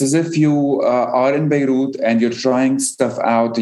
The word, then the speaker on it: Beirut: So the official spelling in English is B-E-I-R-U-T, while the French spelling, it's B-E-Y-R-O-U-T-H. But we wanted to go with what it sounds like, or however you want, or however you say it or pronounce it. So it's Beirut, B-A-Y-R-U-T, which is more closer than the other spellings.